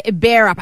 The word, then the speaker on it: Bearup: All right, his name is Greg Bearup.